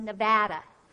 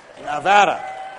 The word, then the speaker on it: Nevada: knows that it's pronounced Nevada. – That's correct. Nevada.